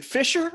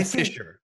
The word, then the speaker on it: fissure: Is it Fisher or Fissure? Microfissure. – No. – I'm going to say Fissure. Microfissure. Like microfissure. – Fissure.